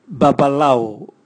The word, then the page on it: babalau: Raga